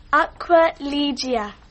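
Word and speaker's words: Aquilegia: Aqua Ligia.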